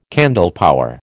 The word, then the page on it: candlepower: American English